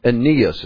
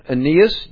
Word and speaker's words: Aeneas: And Peter said to him, Aeneas is probably a better pronunciation. Aeneas, Jesus Christ heals you.